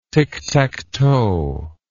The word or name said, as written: ticktacktoe